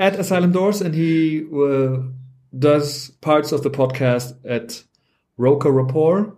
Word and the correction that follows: Roker Report: or how would you say it, Simon? – Roker Report.